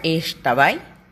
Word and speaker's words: Xtabay: Se pronuncia Ishtabai, una mujer yucateca fantástica, con cabello largo y oscuro y vestido blanco, similar a una sirena.